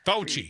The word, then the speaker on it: Fauci: Fauci.